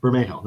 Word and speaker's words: Bermejo: Yeah, Bermejo. I can never pronounce that. Bermejo, that's it. – Yep, you got it.